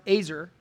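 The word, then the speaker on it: ezer: In the original Hebrew, the word is azer. Go ahead and write that down. It's E-Z-E-R, but it's pronounced azer.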